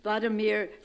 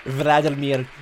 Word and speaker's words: Volodymyr: Members of Congress, I have the high privilege and distinct honor of presenting to you the President of Ukraine, Volodymyr Zelensky. Slava Ukraina! Slava Ukraina! – She pronounced his name wrong. – Vladimir Zelensky.